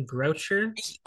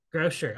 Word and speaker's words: Grocher: Oh, I, I was saying your name right, Kim Grocher. – Grocher, yep. – Grocer. – Okay, mm-hmm.